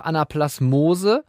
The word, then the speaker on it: Anaplasmose: Ich habe auf meinem Zettel aber noch Anaplasmose und Ehrlichiose auf dem, ich hoffe, ich habe das richtig ausgesprochen, auf dem Zettel stehen. – Ja, perfekt.